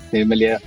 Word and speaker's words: Nirmalya: I think Nirmalya is the correct pronunciation.